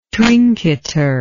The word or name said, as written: trinketer